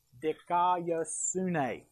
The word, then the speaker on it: dikaiosune: This is how we would pronounce it. Dikaiosune.